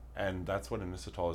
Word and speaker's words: Inositol: I'm taking the Inositol. Did I say it right? – Yeah.